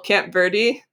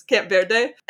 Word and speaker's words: Camp Verde: And they actually ended up in this place called, I know this is a really bad anglicized pronunciation, but this is what people in Texas called. It's called Camp Verde, Texas, Camp Verde.